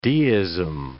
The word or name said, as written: deism